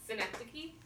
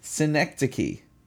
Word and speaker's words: synecdoche: Synecdoche. – Synecdoche.